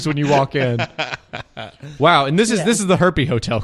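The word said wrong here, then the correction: Herpe: Herp with a silent E at the end, so just Herp Hotel.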